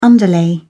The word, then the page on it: underlay: British English